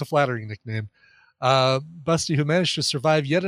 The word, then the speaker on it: Basti: Busty is the phonetic version for the German name Basti. B-A-S-T-I, but it's pronounced Busty.